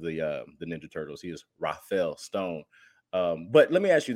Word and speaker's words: Rafael Stone: And we talk about Rafael Stone so little that I'm pronouncing his name like he's one of the Ninja Turtles, and I apologize for that.